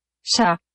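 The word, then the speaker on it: chat: Sha.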